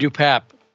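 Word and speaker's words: DePape: There's nothing I'm seeing about this guy, David DuPape. That's apparently how you pronounce his name, according to his lawyer.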